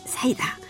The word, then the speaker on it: cider: Ajoutez-y des glaçons pour que le tout reste frais, puis versez-y une bouteille de cider Attention, non pas de cidre, mais de limonade. On prononce comme ça chez nous, saïda.